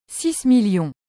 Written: 6000000

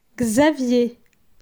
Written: Xavier

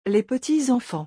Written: Les petits-enfants

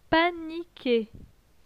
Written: panique

